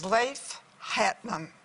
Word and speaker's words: Leif Hetland: His name is Leif Hetland. Did I say it right? – Leif Hetland, that's right.